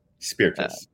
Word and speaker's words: Spirtas: You correctly spell my last name, Spirtas.